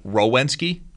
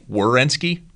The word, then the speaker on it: Werenski: If I say it too quickly, I'll say it like Rowensky, and it's Werenski.